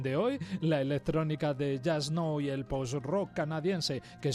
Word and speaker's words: Jusk-No: Hoy un dúo llamado Just Know, aunque se escribe Jusk-No.